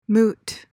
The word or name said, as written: moot